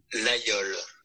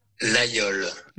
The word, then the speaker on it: Laguiole: Laguiole. – That's how you say it. – French man saying it for you. – One more time. – Laguiole. – Laguiole.